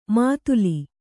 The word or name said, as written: ಮಾತುಲಿ